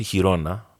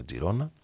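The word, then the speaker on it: Girona: Πάντως η Χιρόνα, εκεί καταλήγει ο φίλος μου και πρώην συνάδελφος Γιώργος, ο οποίος έχει φοβερή φωνή και το λέει, το βαθιά, ότι αυτή είναι η σωστή προφορά, αλλά στα, στα καταλανικά τη λένε Τζιρόνα.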